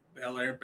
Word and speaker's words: Belair: Blair? Bianca Belair. I said it right? – Belair.